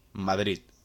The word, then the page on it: Madrid: Spanish